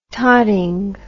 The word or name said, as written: totting